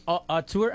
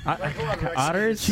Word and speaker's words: auteur: All the biggest are auteurs. How do you say it? – Auteurs. – Auteurs. Otters.